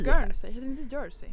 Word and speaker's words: Guernsey: Tulip Guernsey. – Jag får ursäkta liksom uttalet, men det är helt rätt. Du uttalas Guernsey.